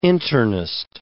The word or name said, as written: internist